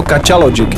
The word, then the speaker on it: Kača Logic: Je to přesně tak a jestli jste se zarazili na tím jménem, jsme to nevyslovili nějak nějak špatně, protože se správně, jmenuje se to Kača Logic.